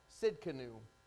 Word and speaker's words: Tsidkenu: Tsidkenu. Is how you say it.